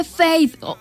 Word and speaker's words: Feid: Además, a ver, muchos se preguntarán por qué Faith, se escribe F-E-I-D, pero se dice que su significado es de Faith, que es fe en inglés.